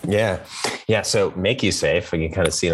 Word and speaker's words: MakuSafe: We put the line over it, the Macrone, as it's called, to give it the long A sound. So Make You Safe.